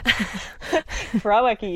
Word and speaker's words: Krawiecki: Krawiecki, you did a great job.